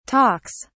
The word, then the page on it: talks: British English